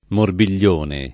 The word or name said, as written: morbiglione